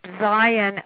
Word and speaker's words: Zien: The voice of Howard is provided by Chip Zien, I believe that's how you pronounce his name. – It's Chip Zien.